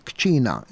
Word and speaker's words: kċina: U hemmhekk allura aħna mit-Taljan il-kelma għaddiet għal-Malti fil-forma kċina. Interessanti imma, ara, aħna għandna it-Taljan ku ċina, mela għandek l-ewwel sillaba ku qabel ma tiġi għa ċi, alright? U aħna dik l-ewwel sillaba tajarniha kċina, ku ċina, kċina, alright?